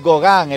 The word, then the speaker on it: Gauguin: ¿Un qué? ¿Un Sogán? – Un Gauguin, el cuadro. – Ok. No, Gauguin. No lo había entendido bien. – Ah, bueno, es mi pronunciación del francés. Es un francés cerrado.